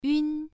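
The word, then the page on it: yun: tone 1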